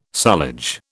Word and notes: sullage: British English

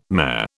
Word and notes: mayor: British English

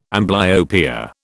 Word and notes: amblyopia: British English

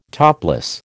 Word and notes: topless: American English

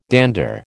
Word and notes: dander: American English

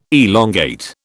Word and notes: elongate: British English

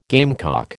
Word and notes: gamecock: American English

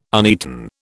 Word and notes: uneaten: British English

